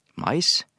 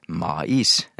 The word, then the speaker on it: mais: Og da er det mais med a og i, men som en diftong, mais, og ikke mais. Mm. ikke to stavinger, men en diftong, men med ai.